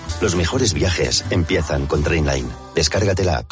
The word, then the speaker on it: Trainline: Se escribe mejor precio garantizado. Se pronuncia Trainline. Descarga la app de Trainline y ahorra comparando todos los trenes y tarifas.